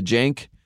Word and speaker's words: Jank: But I want to say Tim Jankovic, aka the Jank. They call him Jank. I've never heard something that's supposed to be positive that rhymes with shank, but it's called the Jake.